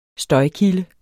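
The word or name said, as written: Støjkilde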